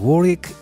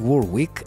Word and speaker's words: Warwick: Πάμε να βρούμε την Dionne Warwick ή Warwick, αν είστε Αμερικανοί, αναλόγως του ποια προφορά θέλετε του ονοματός της, τη Βρετανική ή την Αμερικανική, η οποία παρέα με τον Jeffrey Osborne μας τραγουδά Love Power.